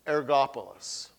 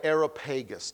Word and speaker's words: Areopagus: The advocate of Areopagus, or Areopagus, depending on how you want to pronounce it.